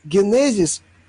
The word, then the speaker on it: генезис: если парашют через «у» будет и генезис, ударение, генезис, ударение на втором слоге.